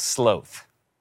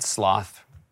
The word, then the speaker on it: sloth: someone said, which is some real, I think some parts of the world, they pronounce things differently, that sloth is pronounced sloth. I mean, come on, right? I'm not going to start saying sloth. I would never remember it. That sloth rhymes with slow.